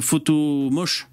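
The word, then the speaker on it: Photomosh: mes jingles, je les fais juste avec euh, photo moche. Photo Moche, c'est un site gratuit. Euh, moche, ça s'écrit M-O-S-H. Photomoche.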